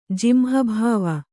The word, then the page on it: ಜಿಂಹಭಾವ: kannada